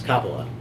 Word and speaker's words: Coppola: It's Coppola.